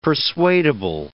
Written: persuadable

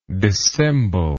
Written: dissemblingly

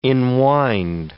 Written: enwind

